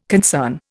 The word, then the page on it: concern: Indian